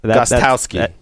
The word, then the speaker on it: Gostkowski: Kapowski? – You got to pronounce the T at the end of the Gost. – Gostkowski.